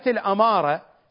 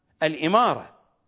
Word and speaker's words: الإمارة: فهو ردد هذه الكلمة أكثر من مرة على أنها الهوية والصحيح الهوية، وردد كذلك كلمة الأمارة خارج الرواية والصحيح أنها الإمارة، فالأمارة علامة والإمارة نظام حكم.